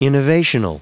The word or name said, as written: innovational